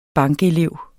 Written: bankelev

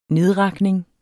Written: nedrakning

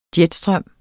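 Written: JETSTRØM

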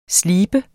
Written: sleben

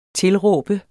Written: tilråb

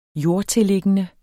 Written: jordtilliggende

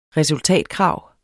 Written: resultatkrav

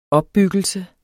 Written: opbyggelse

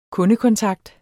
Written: kundekontakt

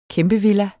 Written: kæmpevilla

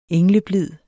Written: engleblid

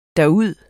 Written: derud

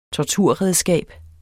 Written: torturredskab

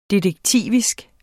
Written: detektivisk

